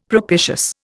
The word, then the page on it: propitious: Indian